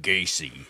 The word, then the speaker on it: Gacy: And their first season, they did John Wayne Gacy. – Yeah. Mm-hmm. – If I said it correctly. – Yep. Gacy.